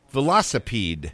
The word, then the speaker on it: velocipede: And your history moment today is about a horse and rider versus a big wheel velocipede.